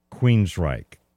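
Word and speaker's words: Queensrÿche: You say, well, now, how do you know it's pronounced Queensryche? Q W E E N R Y C H E.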